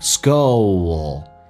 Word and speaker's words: scold: 十年之后我希望 I wish 这个发音短元音，你看一下轻轻打开口腔 wish I wish my parents scold 指的是责备，这个单词很难发，先发 O，然后呢 L 这个发音舌尖抵住你的上齿根上齿龈那个部分叫做 owl skull， one more time skull skull 地不发音气流阻塞 scold me again， me again，记住 me again 可以中间加个 y，这是元音的连音。